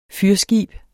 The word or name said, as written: fyrskib